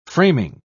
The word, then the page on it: framing: British English